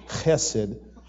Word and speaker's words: Chesed: You're going to kind of catch in the back of your throat because it's a Hebrew word. Chesed. Okay? There you go. Yeah, if you're spitting on somebody, you're saying it right.